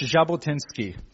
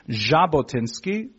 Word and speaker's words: Jabotinsky: So his proper legal name is Vladimir Yevgenievich Jabotinsky. The pronunciation is properly a Z sound, which is actually a specific letter in the Cyrillic alphabet.